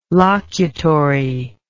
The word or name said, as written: locutory